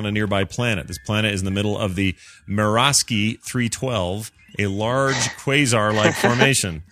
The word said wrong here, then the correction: Mirasaki: Am I saying that right? – Mirasaki. – It's not Mirasky playing left forward for the Philadelphia Flyers.